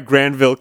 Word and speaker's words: Granville: Wow, that's why Granville County is pronounced with a hard G. – With a hard G, yeah. This is not a J. This is a G right here.